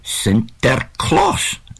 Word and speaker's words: Sinterklaas: Well, apparently, it evolves from the Dutch St. Nicholas, which is pronounced Sinterklaas.